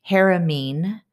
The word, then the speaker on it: Haramein: So if you aren't familiar with the physicist named Nassim Haramein, his last name is spelled H-A-R-A-M-E-I-N.